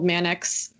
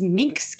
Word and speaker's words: Manx: I always thought it was Minx cat. That's crazy. I've been saying that wrong my whole life.